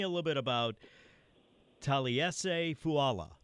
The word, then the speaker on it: Fuaga: Because, the years he's played at Oregon State and even Senior Bowl, Combine, they always said Fuaga. Mm-hmm. But, he told us last night, the G is silent. But he said, if you want to call me Fuaga, that's good.